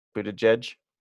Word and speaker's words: Buttigieg: Buttigieg? – Yeah, I've practiced.